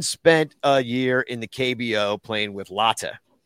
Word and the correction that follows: Lotte: Am I saying that right? – Lottie. Yes. It's probably more like the Lotte Giants.